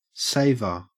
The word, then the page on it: saver: Australian English